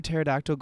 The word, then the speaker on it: pterodactyl: Why can't you hear a pterodactyl going to the bathroom? – Because the P is silent.